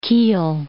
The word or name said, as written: Kiel